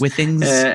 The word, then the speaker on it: Withings: Am I pronouncing it right? Withings? – Yes. Withings, yes. – Withings? – Withings.